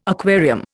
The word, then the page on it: aquarium: Indian